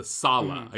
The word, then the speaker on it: Tsala: Well, you know, the interesting thing too is when it's actually in the spirit voice, the Sala, am I pronouncing that correctly? T-S-A-L-A. – Yeah.